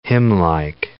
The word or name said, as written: hymnlike